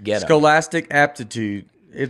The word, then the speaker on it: Scholastic: Scholistic. Well, how do you say it? – Scholastic. – Scholastic. – Jeez. Yeah, you got to do the whole thing.